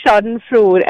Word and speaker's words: schadenfreude: So it's schadenfreude, and it's S-C-H-A-D-E-N-F-R-E-U-D-E.